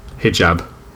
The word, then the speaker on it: hijab: Yeah, she did a porn in a hijab. And is that how you say it? Hang on. Pronunciation. Hijab. – Hijab. – Hijab. Okay, I'm good.